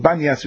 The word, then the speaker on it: Banyas: The reason why it's called Banyas, because that's the Arabic pronunciation for Pamias, but that's one and the same.